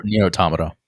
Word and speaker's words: Nier Automata: Is it Nier Automata? Is that how you say it? – Nier Automata.